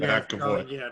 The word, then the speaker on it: McAvoy: McAvoy.